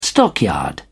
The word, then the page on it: stockyard: British English